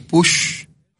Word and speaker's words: Puig: También la raíz está catalana como push. Sabrá que ya habíamos hablado. – Sí, que se escribe puig, ¿no? Puig, ¿no? Y se pronuncia push.